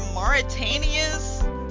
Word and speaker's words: Mauritania: All right, well, let's crack into Mauritania's...